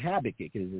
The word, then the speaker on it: Habakkuk: In the book of Habakkuk, I always say that wrong. Habakkuk is his name.